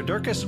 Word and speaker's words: Durkis: Sarah Durkis, with Rhymes with Circus,